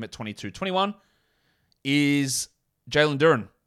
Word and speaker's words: Duren: And his name is Jalen Duren. I know it's spelt slightly differently, one letter different, but that is what that is.